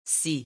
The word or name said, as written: scie